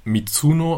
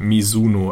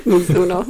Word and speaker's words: Mizuno: Das hat nämlich auch der User Biolek unter einer der News geschrieben, ähm, dass sie nicht Mizuno ausgesprochen wird, sondern Mizuno, also mit einem weichen S. So, das können wir ab jetzt dann auch richtig machen. Mizuno, okay.